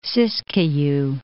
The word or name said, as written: Siskiyou Mountains